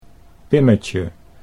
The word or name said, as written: Pimmetje